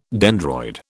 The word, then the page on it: dendroid: British English